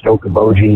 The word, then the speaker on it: Okoboji: But I'm calling to say, Josh, it is pronounced Okoboji. You said it right.